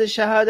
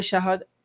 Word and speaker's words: shahada: Is we call it shahadat? It's the shahadat. They call it the shahada because for some reason they don't pronounce the T sound at the end, but they call it the Shahada, we call it the Shahadat.